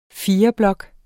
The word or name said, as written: fireblok